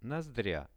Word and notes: nozdria: Russian

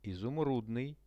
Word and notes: izumrudnyj: Russian

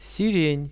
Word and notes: siren: Russian

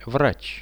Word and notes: vrach: Russian

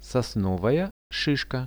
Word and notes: sosnovaja shishka: Russian

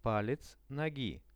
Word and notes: palec nogi: Russian

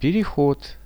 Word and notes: perehod: Russian

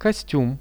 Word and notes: kostium: Russian

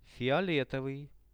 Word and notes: fioletovyj: Russian

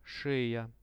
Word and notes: sheja: Russian

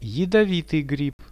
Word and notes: jadovityj grib: Russian

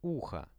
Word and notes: uho: Russian

